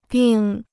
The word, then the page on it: 病: bìng